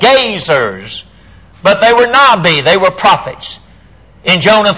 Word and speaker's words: Nabi: It comes from a Hebrew word, that is if you're studying the prophets in the Old Testament, a Hebrew word, N-A-B-I, pronounced Nabi.